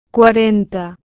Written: 40

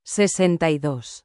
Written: 62

